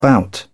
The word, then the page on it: bout: British English